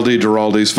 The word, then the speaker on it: Duralde: How do you say it? – You know, it depends. Duralde's fine. Okay.